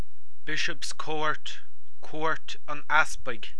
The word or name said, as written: Cúirt an Easpaig